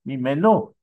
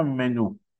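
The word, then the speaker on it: Mimenu (ממנו): Mimenu, ça s'écrit même Nun Vav. Même Nun Vav, ça se lit aussi Mimeno. Pas seulement Mimenu.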